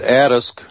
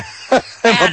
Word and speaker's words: Adask: Pronounce it Adask. I'm Adask. About the only one who does. Everybody else says Adask or Adask or so. I pronounce it Adask. – Okay. – Like Adams.